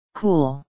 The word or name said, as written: cool